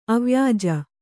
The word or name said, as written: ಅವ್ಯಾಜ